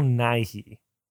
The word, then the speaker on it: Nighy: I will say his name is Bill Nighy. – You said Nighy. Like Nahe, like the wine region in Germany.